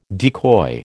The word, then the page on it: decoy: American English